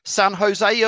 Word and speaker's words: San Jose: San Jose Earthquakes.